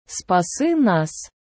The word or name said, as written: спаси нас